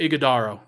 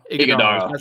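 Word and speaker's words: Iguodala: Oso Iguodaro, actually, you pronounce that name. Oso Iguodaro. – Iguodaro. – Sound pretty good? Okay. – You got it.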